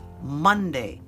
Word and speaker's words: Monday: It is pronounced Monday. Just like Sunday. Sunday is S-U-N, but Monday is M-O-N. Why do we pronounce the O and the U exactly the same? I don't know, but that's what we do.